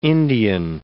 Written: Indian

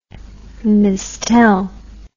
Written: mistelle

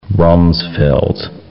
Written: Rumsfeld

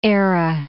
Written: Eire

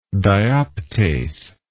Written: dioptase

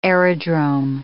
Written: aerodrome

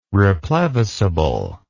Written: replevisable

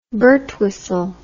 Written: birtwistle

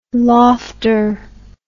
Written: lofter

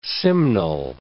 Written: simnel

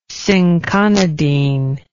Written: cinchonidine

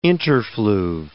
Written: interfluve

